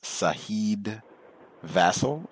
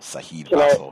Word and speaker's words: Saheed Vassell: His name, I think, is Saheed Vassal, I think is the correct pronunciation. S-A-H-E-E-D-V-A-S-S-E-L-L. Saheed Vassal.